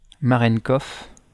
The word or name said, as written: marenkoff